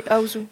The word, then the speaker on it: Ozu: Tu parlais euh, d'Ozu et j'étais, euh, c'est Aouzou que ça se prononce. – Oui, Aouzou.